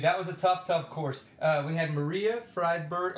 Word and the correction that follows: Forsberg: Oh, God, I said it wrong. What is it? Forsberg? Forsberg. – Yes. – Maria Forsberg in first.